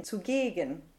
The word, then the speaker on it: zugegen: Ja, so in this case, zugegen, the ZU is actually not a prefix. It's part of the actual word. It's part of the adverb here. And so it's not stressed. The syllable that's stressed is the gegen, zugegen.